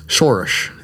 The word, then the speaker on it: Soros: but George Soros is, Shorish is actually how you say it, but George Soros is, he has installed over 20 state attorney generals and backed them up and financed their campaigns.